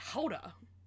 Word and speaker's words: Gouda: Or do you just really want some Gouda for the rest of your life? I don't know. Gouda's really tasty. In Dutch, it's pronounced Houda.